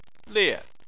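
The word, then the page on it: lie: tone 4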